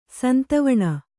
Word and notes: ಸಂತವಣ: kannada

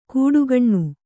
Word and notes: ಕೂಡುಗಣ್ಣು: kūḍugaṇṇu

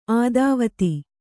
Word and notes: ಆದಾವತಿ: ādāvati

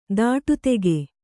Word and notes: ದಿಟ್ಟಿತಾಗು: diṭṭI tāgu

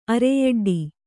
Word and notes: ಅರೆಯೆಡ್ಡ: Kannada

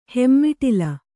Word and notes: ಹೆಮ್ಮಿಟಿಲಿ: hemmiṭili

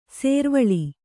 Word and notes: ಸುರ್ವಾಲ್: survāl

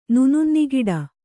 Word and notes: ನುನುನ್ನಿಗಿಡ: Kannada

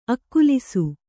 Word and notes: ಆಕ್ಲಾಸ: āklāsa

